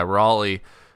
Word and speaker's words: Raleigh: It's spelled like Raleigh Durham. – Yeah. – So yeah, Raleigh.